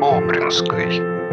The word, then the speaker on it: Бобринский: Граф Бобринский Алексей Алексеевич. Второе произношение фамилии Бобринской.